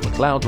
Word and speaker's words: MacLeod: Additional music is Kevin MacLeod, because I'm told it's pronounced McLeod rather than McLeod, so I'm going for that until corrected.